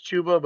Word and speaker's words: Chuba: I believe it's pronounced Chuba, but Chuba Hubbard just rolls off the tongue a little bit better.